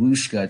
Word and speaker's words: Whooshkaa: I transferred to this Australian based hosting service called Whooshkaa. It's spelled W H O O S H K A A, two A's at the end.